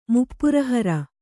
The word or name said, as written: ಮುಪ್ಪುರಹರ